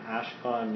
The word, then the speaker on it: Ashkon: I'm Ashkon Nima. You can spell that A-S-H-K-O-N, last name is N-I-M-A.